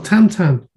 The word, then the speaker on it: Tintin: I like the fact that the Troubleshooters is kind of like Tintin or Tantan, as he's apparently, that's how you say it.